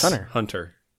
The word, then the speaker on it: Hunter x Hunter: Hunter X Hunter. Hunter. You don't pronounce the X, but the X is in there. It's Hunter Hunter.